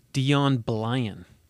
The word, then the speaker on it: Deon Blyan: Deon Blyan.